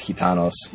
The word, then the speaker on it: Gitanos: you know, Gitanos, I mean, if you're doing it right, you pronounce the G like an H, and it's Gitanos.